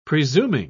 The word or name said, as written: presuming